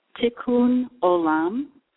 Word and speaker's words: Tikkun Olam: Tikkun Olam.